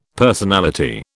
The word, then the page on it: personality: British English